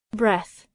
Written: breath

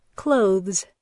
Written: clothes

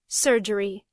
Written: surgery

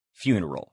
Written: funeral

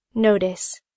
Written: notice

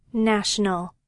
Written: national

